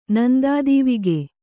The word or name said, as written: ನಂದದೀವಿಗೆ